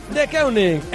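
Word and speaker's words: Deceuninck: Porque a lo mejor nos pasa lo mismo que con De Keuning, que hemos dicho de todo menos lo que había que decir, hasta que se han vuelto amigos nuestros y nos hemos presentado De Keuning y nosotros, y ahora ya sabemos que las ventanas, los perfiles de las ventanas son De Keuning.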